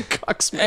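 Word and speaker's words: Czuczman: It's a Cuxman. – I mean, I probably mispronounced it. It's C-Z-U-C-Z-M-A-N. – No, I think you nailed it.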